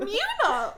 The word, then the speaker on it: Muna: Muna! Like Luna!